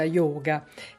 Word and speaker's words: yoga: Ricordiamo che yoga in sanscrito si dice appunto con la O chiusa, yoga.